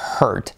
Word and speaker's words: hurt: Fijaos cómo se pronuncia con la R genérica de sister paper, que no tiene ninguna vocal delante, porque fíjate que la prueba es que sister es con E y es sister and hurt es con una U y suena lo mismo.